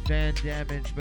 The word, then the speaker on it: VNDMG: Balance and Van Damage, but it's spelled, what is it, V? – V-N-D-M-G.